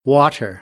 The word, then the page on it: water: American English